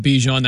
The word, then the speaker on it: Bijan: I was saying Bijan, you're saying Bijan. We're going to go with Bijan. That's the correct way to say Mr. Robinson.